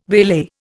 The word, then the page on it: waylay: Indian